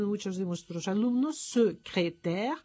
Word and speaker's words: secrétaire: Hay una palabra que es secrétaire, que tiene esas tres es.